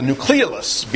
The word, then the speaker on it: nucleolus: But there's a region within the nucleoplasm, the stuff inside the nucleus, that is it's called the nucleolus. Be careful with that word, too. Nucleolus and nucleus are not the same thing, but you can easily mispronounce that word when you're reading it to yourself as nucleus.